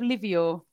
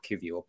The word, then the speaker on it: Kivior: Jakob Kivior. I think I've said it right. – Well done. – Do you like it? I like the fact you're going with phonetics. Talk to me about Jakob Livior. – Kivior.